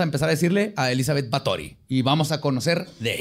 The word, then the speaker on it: Báthory: Pues, de hecho, investigando este caso, descubrí que se pronuncia Batori. Y así le voy a decir porque está padre. Batori.